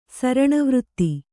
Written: ಸರಣವೃತ್ತಿ

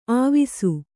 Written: ಆವಿಸು

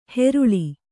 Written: ಹೆರುಳಿ

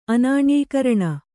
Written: ಅನಾಣ್ಯೀಕರಣ

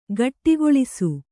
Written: ಗೂಡಗೊಳಿಸು

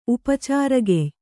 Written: ಉಪಚಾರಗೆಯ್

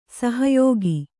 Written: ಸಹಾಯಕ

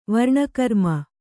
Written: ವರ್ಣಕರ್ಮ

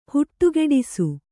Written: ಹುಟ್ಟುಗೆಡಿಸು

